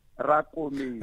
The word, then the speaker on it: Rakometsi: Just a small correction. My surname is pronounced Rakometsi.